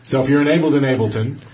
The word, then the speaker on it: Ableton: and it is pronounced Ableton, and we have confirmed that with many Germans. – Oh, nice. – It is definitely 100% Ableton.